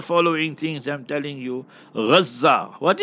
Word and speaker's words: Gazza: But proper pronunciation, Islamically, Arabic, Gaza. A tajdeed, a shadda on the za, Gaza, like that. So reze, you know, so like that, on that tajdeed there is G-A-Z-Z-A.